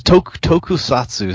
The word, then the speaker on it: Tokusatsu: So, you know, I know you have a couple of, what was it, the Lost Tosusaku, did I say that right? – You're close. Tokusatsu is the Japanese word for special effects.